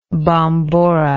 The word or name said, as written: bombora